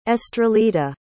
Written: estralita

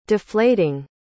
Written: deflating

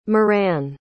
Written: moran

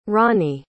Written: rawnie

